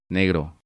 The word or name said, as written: Negro